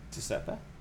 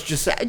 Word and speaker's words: Giuseppe: Yeah, you said it wrong. – Giuseppe? – Oh, no, it's Giuseppe. – Giuseppe is like a nickname. – Yeah. – But it's Giuseppe.